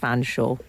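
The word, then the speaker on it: Featherstonehaugh: The surname spelt Featherstone Hall is pronounced Fanshawe. Yes.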